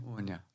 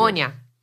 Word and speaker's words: Anya: And I keep pronouncing Anya wrong. I'm trying really hard. The main character's name is Anya. – Anya. Very good. – Anya. Yep.